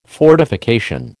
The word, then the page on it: fortification: American English